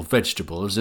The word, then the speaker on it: vegetables: The word vegetables, for example, looks like it should have four syllables when we see it written. But in fact, it only has three, and most of the vowels just sound like "uh."